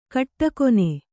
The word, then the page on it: ಕಟ್ಟಕೊನೆ: kaṭṭakone